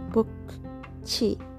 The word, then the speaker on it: 不客气: And the first two sound, it sounds like you are reading book, the book. So you say 不客气. 不客气.